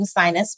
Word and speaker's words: Signes: So SignesGlobal, one of the first reasons that I even came up with that name, Sinus, which is spelled S-I-G-N-E-S. And yes, you are correct in your corrected pronunciation, because that's a French word that translates to signs in English.